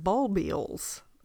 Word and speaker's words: bulbils: And they're called bulbils, I think is the correct pronunciation.